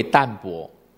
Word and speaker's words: 淡泊: 那淡泊呢，又可以称为它另外一个写法，我有写在黑板上哈，也是读为淡泊，它那个字不是读怕哦，它有两个读音的，这个音是读泊。